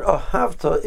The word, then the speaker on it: ahavta: The word ahavta is stressed on the second to last syllable.